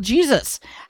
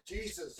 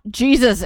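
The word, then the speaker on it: Jesús: So basically his name is spelled J-E-S-U-S. Okay. Yes, that is spelled Jesus. However, it is pronounced Jesus. – Jesus. – It's not Jesus. It's Jesus.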